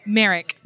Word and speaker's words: Merrick: And Saige, in your last name, please pronounce it. – Merrick. – Merrick. – Like the elephant man.